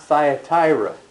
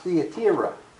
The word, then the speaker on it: Thyatira: It's not Thyatira. It's pronounced Thyatira. Thyatira. Yeah.